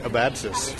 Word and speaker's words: Abadzis: I'm here at Comic-Con at the First Second Books and Macmillan Publishing booth with Nick Abatsis, who, did I say it right? – You did, yes.